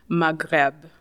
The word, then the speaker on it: Maghreb: And then they have the, I'm going to say this, I say this terribly, Maghreb.